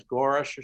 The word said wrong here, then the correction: Gorsuch: or something? We have Gorsuch. Gorsuch.